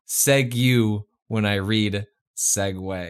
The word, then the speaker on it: segue: Segue, which was pronounced seg-you, and segue, pronounced segue.